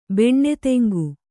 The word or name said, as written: ಬೆಣ್ಣೆತೆಂಗು